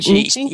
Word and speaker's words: Nietzsche: Nietzsche? Nietzsche? – Yeah, mm-hmm.